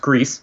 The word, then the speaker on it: Gris: So Gris is pronounced Grease, whoops, sorry, and is also French for gray.